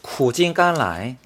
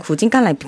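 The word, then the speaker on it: 구진깐라이 (苦尽甘来): 구진깐라이. – 네. 구진깐라이.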